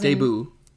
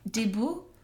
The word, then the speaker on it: debut: Debut. – debut. Sorry, I didn't know that was the correct pronunciation.